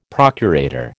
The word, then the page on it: procurator: American English